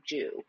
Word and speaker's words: Jiu: I mean, it's spelled J-I-U, but they keep saying Jiu.